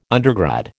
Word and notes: undergrad: American English

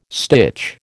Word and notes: stitch: American English

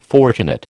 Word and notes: fortunate: American English

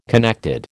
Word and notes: connected: American English

